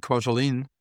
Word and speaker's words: Kwajalein: So the island is called actually Kwajalein. It's spelled in a weird way. You cannot guess the name from when you... It's K-W-A-J and so on.